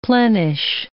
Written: plenish

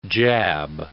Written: jab

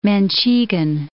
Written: Manchegan

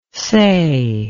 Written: sei

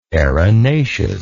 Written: erinaceous

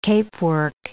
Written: capework

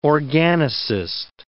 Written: organicist

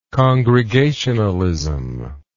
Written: congregationalize

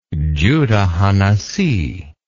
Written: Judah ha-Nasi

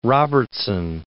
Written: Robertson

